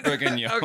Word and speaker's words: bourguignon: Well, let me correct you. I'm sorry. I don't mean to correct you. It's beef bourguignon. – Okay, sorry. I'm never going to get that pronunciation correct.